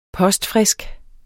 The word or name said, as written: postfrisk